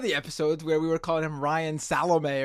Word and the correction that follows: Salame: spelled Salame, but it is pronounced Salem, which I learned.